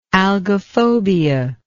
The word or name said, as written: algophobia